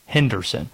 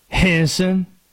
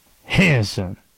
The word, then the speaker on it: Henderson: Henderson. You want to know how the people in Henderson pronounce it? Henson. Two syllables. Henson.